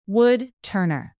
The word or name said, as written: woodturner